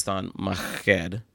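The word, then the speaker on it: Thon Maker: It's not Maker. It's Thon Maked. You know, I'm not from Africa, so go, you know, you figure that out on your own. But that's technically how you say it.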